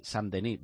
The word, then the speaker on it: Saint-Denis: Sí, últimamente cuando escucho continuamente, es normal, ¿no?, que la gente dice Saint-Denis. Bueno, primero no es Saint, es Saint, pero no es Denis, es Denis. – Denis. – Es Denis. – Exactamente. – No, sí, la S no se pronuncia, pero bueno.